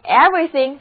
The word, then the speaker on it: everything: Everyone 的意思是每个人，大家， Everyone 就是每一个的意思，所以 Everything 就表示每件事或者事事。注意第二个字母 E 不发音。